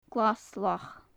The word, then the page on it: glas-lach: gaelic